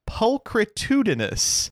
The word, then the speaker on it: pulchritudinous: Am I the asshole for calling a woman pulchritudinous? – Wow. – Oh my god. – I wanted to check to make sure that you pronounced it right, and you did. You got it. You banged that out.